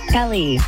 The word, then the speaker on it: Kelly: Kelly.